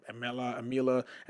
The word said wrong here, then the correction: Amalekites: and I was just trying to pronounce it. It was funny. It wasn't that funny. It was kind of sad. But the Amalekites.